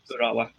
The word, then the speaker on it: Urawa: So He's been linked with Ura, Uwara Red Diamonds. I know, I've completely butchered that, haven't I? – Mitsubishi team. Urawa, yeah.